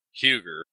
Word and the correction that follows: Huger: but that's how you stand out as not being a local, because the locals call that Hugie. – Wait, how they say Hugie and not Huger? – There's no R on it. It's huge E. For the locals, there's no R. Even though it's spelt with an R, they don't pronounce the R.